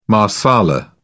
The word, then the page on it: marsala: British English